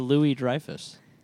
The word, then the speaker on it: Louis-Dreyfus: Julia Louis-Dreyfus. – Yeah. – Is that how you say her name? – Yeah. – Were you saying Lewis all these years? – Yeah, all these years.